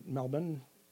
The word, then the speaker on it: Melbourne: I had one done in Australia, in Melbourne. I think that's how they pronounce it now. – I think you're right. – Really? – Well, yeah. – I always get it wrong. – They don't pronounce the hard R. – They don't pronounce the R, yeah.